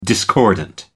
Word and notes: discordant: American English

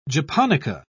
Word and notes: japonica: British English

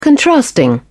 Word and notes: contrasting: British English

